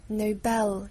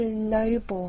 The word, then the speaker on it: Nobel: 诺贝尔奖是 Nobel，重音在 L Bell 上面，就不是 Noble 啊，是 Nobel Nobel 音音，那美音就是 Oh no Nobel Nobel。